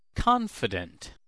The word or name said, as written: confident